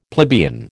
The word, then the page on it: plebeian: American English